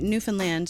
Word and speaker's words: Newfoundland: Newfoundlanders say it Newfoundland to rhyme with understand.